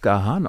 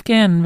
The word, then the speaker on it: Gahan: Wusstest du eigentlich, dass Dave Gahan Dave Gahan ausgesprochen wird? – Ich sag Dave Gahan.